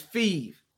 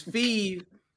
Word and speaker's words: Phife: It's Phife. It's Phife, but Skinny Boy Fizz is an AKA. – Okay. – I'm Phife.